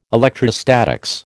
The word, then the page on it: electrostatics: American English